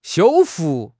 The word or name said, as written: 修复